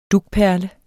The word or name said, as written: dugperle